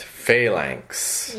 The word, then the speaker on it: phalanx: The flanx. – Pronounced phalanx.